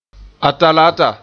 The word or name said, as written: Atalaata